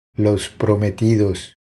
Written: Los prometidos

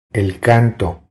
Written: El canto

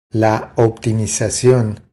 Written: La optimizacion

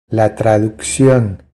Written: La traduccion

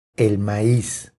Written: El maiz

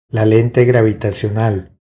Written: La lente gravitacional

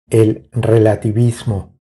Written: El relativismo

